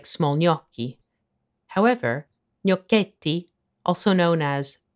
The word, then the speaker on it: gnocchi: Most English speakers pronounce gnocchi in one of two ways, gnocchi in the UK or gnocchi in the US. Here's how you would pronounce it in Italian.